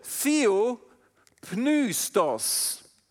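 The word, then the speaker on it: Theopneustos (θεόπνευστος): Dieses Wort auf Griechisch ist das Wort Theo, Theo, Pneustos. So wird es ausgesprochen.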